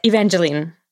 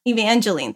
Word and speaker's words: Evangeline: We have The Lore, Jennifer's Body, Evangeline. Is that how you say it? – Evangeline. – Evangeline, thank you.